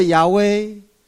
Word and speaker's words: 亚威: 这亚威是希伯来文发音，意思就是我是，我是自由者，直接把意思写出来就 I am，我是。